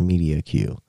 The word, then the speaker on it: MEDIOQ: It's pronounced Medio Q. MEDIOQ.